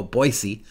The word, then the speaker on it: Boise: or as they say in Idaho, Boise. It's funny, they pronounce it funny in their own state, but I guess they know what they're doing because they live there.